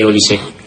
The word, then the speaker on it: Olise: O como Poné, o como Canté, entonces me extrañaba por eso que no se pronunciara la E de Olise.